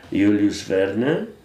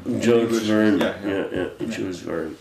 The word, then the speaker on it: Jules Verne: Verne, I know his name only in Polish pronunciation, Julius Verne, like 88... around the world in 80 days. – Jules Verne.